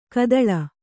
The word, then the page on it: ಘಾತಾಳಿ: ghātāḷi